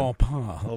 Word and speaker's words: Au Bon Pain: I didn't know that was the correct pronunciation. – Au Bon Pain.